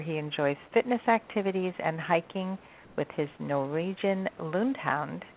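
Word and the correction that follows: Lundehund: Well, did I pronounce, is it Lundhund? – Lundhund. It's pronounced Lundhund.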